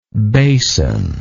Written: bason

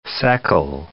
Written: Seckel

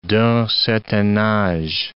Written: d'un certain age

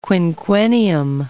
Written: quinquennium

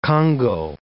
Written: congou

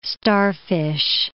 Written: starfish

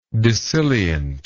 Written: dissilience

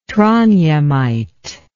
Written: trondhjemite

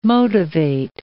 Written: motivate

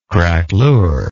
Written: craquelure

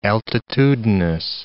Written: altitudinous

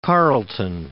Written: Carleton

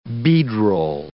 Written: beadroll

